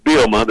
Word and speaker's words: Bielema: And the is it Bielema? That's the proper way to say it, isn't it, Brett? – It's pretty close. It's Bielema.